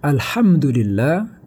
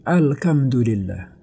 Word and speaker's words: الحمد لله: dia membaca alhamdu ya alhamdulillah tapi dia membacanya alkamdu alkamdulillah atau alhamdulillah atau alhamdulillah ya jadi h huruf h diganti dengan huruf kap Kelihatannya bagi orang yang tidak faham artinya itu sederhana, tidak ada begitu masalah.